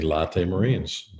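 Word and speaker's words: Lotte Marines: Had four good years in the major leagues, went to Japan to finish his career, and was with the Latte Marines. That's how I pronounce it, Latte Coffee. Roki Suzuki, you might have heard of him, or Suzuki, or Somaki, some kind of Aki, pitched 17 perfect innings.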